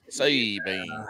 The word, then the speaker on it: Sabine: I got long A's. Sabine. Sabine, at least since they say Sabine.